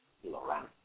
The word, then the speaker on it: Lawrence: Está Lawrence en forma... rhymes with romance. – Lawrence.